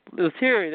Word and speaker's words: Leterrier: I can't pronounce his name. Leterrier, there we go.